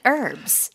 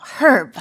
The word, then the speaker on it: herb: 영국이나 호주 같은 경우에는 H 발음을 나게 해요. Herb 하고. 하지만 미국에서는 H 발음이 생략돼서 그냥 Herb라고 발음이 됩니다.